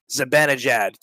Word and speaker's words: Zibanejad: How do you say Zibanejad? – Zibanejad.